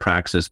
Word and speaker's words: Praxi: It comes from the Greek word praxis, but it's pronounced in some European language, Praxi.